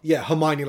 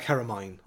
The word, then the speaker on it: Hermione: Yeah, it's one of those things where, like, if you've heard the name, it's like someone's pronouncing... – Hermione wrong. – Hermione, like Hermine, or something like that.